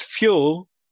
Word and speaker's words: Fyul: We combined them for F-Y-U-L. It was obvious to us that new word should be pronounced Fuel